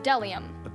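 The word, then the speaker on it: bdellium: And delium. – Delium. It's a gum-like tree resin. Don't they teach you anything in that high school? Delium starts with a silent B.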